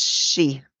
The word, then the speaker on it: sidhe: It's pronounced she. – She. Okay. Mm-hmm. That's what I looked up is not bad. – Like banshee. It's a form of fairy.